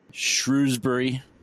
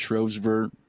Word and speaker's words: Shrewsbury: uh, Shrewsbury, which I think is pronounced Shrovesbury. Shrewsbury. I can never pronounce these ones the correct way.